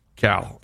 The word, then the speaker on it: Khal: He spells Cal, K-H-A-L. – Cal. I will not mispronounce his name again.